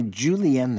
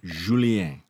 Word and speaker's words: julienne: Sun-dried tomatoes, you want to julienne them. – That sounds good. 1/2 cup of sun-dried tomatoes. – How do you think tomatoes? – That's pronounced in French? – Julienne. – Yeah, julienne.